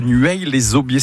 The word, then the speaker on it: Nueil-les-Aubiers: Philippe, qui habite à Nueil-les-Aubiers.